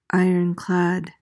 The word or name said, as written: ironclad